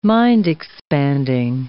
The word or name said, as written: mind-expanding